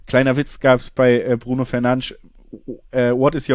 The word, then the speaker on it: Bruno Fernandes: Aber wichtigste Spieler natürlich Bruno Fernandes oder Bruno Fernandes, das kann sich jeder selber aussuchen. Das wäre die korrekte Aussprache, habe ich gelernt.